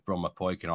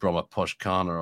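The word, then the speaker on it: Brommapojkarna: The pronunciation, by the way, I once happened to meet a Swedish tipster many years ago, and he got me in the right direction with Bromma Poikin. I always used to say something like Bromma Poshkana or something.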